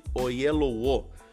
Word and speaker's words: Oyelowo: É, bom, Correndo Contra o Tempo foi lançado em 2019 e no seu elenco nós temos ali o ator é, inglês David Oyelowo. É um nome difícil de pronunciar, mas é isso aí, David Oyelowo.